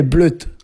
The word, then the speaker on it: blawd: Et en gallois, ça s'écrit B-L-A-W-D, et ça a une prononciation assez variable. Dans le sud du Pays de Galles, on dit bleut, hein, comme par ici. J'avais rencontré un meunier gallois une fois, et il m'avait confirmé que ça se disait bleut.